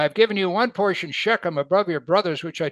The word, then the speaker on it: Shechem: It's Shechem. It's spelled S-H-E-K-E-M, not S-C-H-E-M.